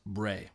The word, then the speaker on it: Brea: They're in Brea, if I didn't mention that. Brea, nice. – California. Also pronounced Bray.